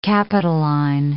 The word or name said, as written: Capitoline